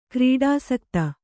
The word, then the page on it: ಕ್ರೀಡಾಸಕ್ತ: krīḍāsakta